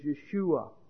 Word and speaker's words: Yeshua: The actual Hebrew pronunciation is Yeshua, which means Jehovah is salvation.